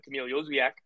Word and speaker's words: Kamil Jozwiak: Charlotte's DP, Kamil Jozwiak, I believe is how it's pronounced in Polish, joined training for the first time this week.